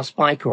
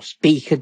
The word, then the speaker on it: Spica: Then on the 11th, it'll be just to the upper left of the star Spica, or Spica, depending on how you want to pronounce it, both in Virgo.